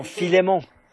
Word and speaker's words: Philémon: Donc, quelqu'un a dit là-bas au fond « Philémon », ce qui, je pense, est vraiment la bonne prononciation.